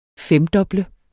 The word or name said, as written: femdoble